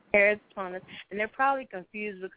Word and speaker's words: keratoconus: How do you pronounce it again? – It's keratoconus.